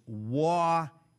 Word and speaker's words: Waukesha: Waukesha is Waukesha. Emphasis on first syllable, short E on second syllable.